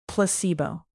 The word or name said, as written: placebo